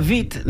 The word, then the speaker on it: Witt: It was Katarina Witt, not Katarina Witt. – Yeah. – Well, because the W is pronounced like a V.